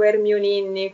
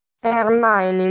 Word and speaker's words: Hermione: Praticamente Krum sbaglia, pronuncia robe tipo Hermioninni, qualcosa del genere, ed è ah, okay. pronunciare. Ma si dice Hermione o Hermione? Non mi ricordo. Hermione, mi sa.